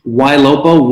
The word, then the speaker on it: Ylopo: Ylopo, Y-L-O-P-O.